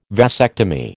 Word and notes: vasectomy: American English

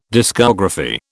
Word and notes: discography: British English

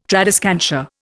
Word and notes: tradescantia: Indian

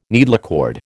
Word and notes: needlecord: American English

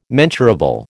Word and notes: mensurable: American English

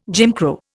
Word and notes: Jim Crow: Indian

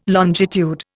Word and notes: longitude: Indian

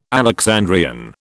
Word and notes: Alexandrian: British English

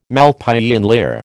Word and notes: Malpighian layer: American English

